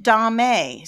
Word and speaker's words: domme: D-O-M-M-E, how do you say it, Dante? I've always said it Dom, just like a Dom. – I know people that say it Dom-A, so I don't know, but she is that just seems, I don't know, but she is a female Dom.